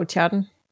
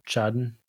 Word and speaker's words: Tjaden: Oh, Tjaden. – Tjaden.